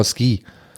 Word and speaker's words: Suki: Und das U spricht man aber im Japanischen nicht aus vom Suki. Deswegen sagt Sean Connery auf Deutsch mindestens zweimal Ski.